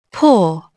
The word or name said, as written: paw